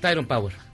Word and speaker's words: Tyrone: ¿Es Tirone Power? – Tyrone Power. – ¿Ves? Es que lo digo mal.